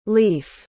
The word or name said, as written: leif